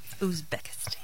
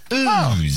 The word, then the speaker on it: Uzbekistan: She won't let you say it wrong. – No. – I learned it on the Olympics. – Ooze. – Not like I knew it. – Ooze. – Yeah. – Uzbekistan? – Yeah.